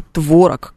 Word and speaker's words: творог: Уже давно пора, в том числе и в словарях профессиональных, вводить норму «творог» как нормальную, верную и вполне себе допустимую,